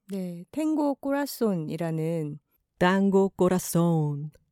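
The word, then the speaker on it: corazón: 아, 김하나 작가가 스페인어 공부를 하고 있고 남미로도 여행을 꽤 길게 갔다 왔었는데 어, 현지어로는 땅고 꼬라손이라고 발음을 하나 봐요.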